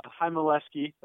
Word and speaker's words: Heimeleski: Yeah, so I got a text sign about 6:30 from Drew Heimeleski. That's how you pronounce his name for all the fans looking at his name. It's him, I-L-ski. So Drew Heimeleski.